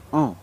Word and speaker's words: en: These words are E, which is spelled Y, and en, which is spelled E-N. And these are quite tricky words to use.